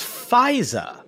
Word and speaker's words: Faizah: You remember that her name is Faiza. – How would I spell that? – F-A-I-Z-A-H.